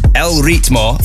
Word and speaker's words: El Ritmo: And before that, shout out to everybody who pointed out I mispronounced Ritmo. There you go. El Ritmo from Eugenio Fico. Big lo siento for getting that wrong.